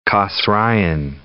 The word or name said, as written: Kosraean